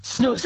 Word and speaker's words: snus: Did I say that right? Snus? – Snus, absolutely. Yeah. Yes, that's correct.